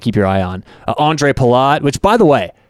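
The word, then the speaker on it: Ondřej: Like the American was A-N-D-R-E. This Andre Palat is O-N-D-R-E-J. – Andre. – Ooh, the J is silent. – Andre. – It's soft J. – Exactly. Real soft J.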